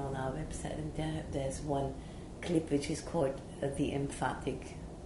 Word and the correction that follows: empathic: how does it word? – Empathic. – I always say it wrong.